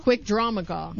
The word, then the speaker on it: Quick Draw McGraw: Well, it's from when Quick Straw McGraw became El Kabong. – Quick Straw McGraw? – Quick Draw McGraw.